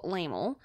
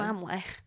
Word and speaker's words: Laemmle: But in 1910, Carl Laemmle mm-hmm. – had, I don't know how to say it, it's L-A-E-M-M-L-E. – That's probably what I would have also said. – Laemmle. – Laemmle.